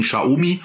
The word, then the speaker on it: Xiaomi: Also ich habe ich hab's mir damals ja so gemerkt, äh, dass es dem Schar seine Umi ist und deswegen Schaumi eigentlich, okay.